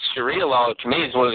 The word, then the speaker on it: Sharia: What is it, Shahara law? Is that how they pronounce it? – Yeah, the Sharia law